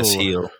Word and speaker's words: Gil: Somebody pronounce that name for me. – Carlos Heel. – Heel, thanks. Nice.